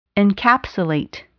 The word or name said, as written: encapsulate